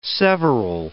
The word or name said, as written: several